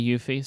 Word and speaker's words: Eufy: So how do you pronounce this company name? Eufy? – I think it's Eufy. Yeah, I hear YouTubers say Eufy, so.